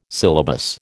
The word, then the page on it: syllabus: American English